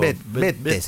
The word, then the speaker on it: Bette: Bette se escribe, Bette.